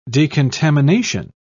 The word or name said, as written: decontamination